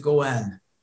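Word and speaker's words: Gowan: And if you want to pronounce it Gowen, it's okay with Gowen, I'm sorry. You know what's funny? I wrote Gowen and then I said Gowen because of the Gowanus in Brooklyn. I keep saying Gowen and it's Gowen. I am sorry.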